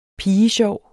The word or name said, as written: pigesjov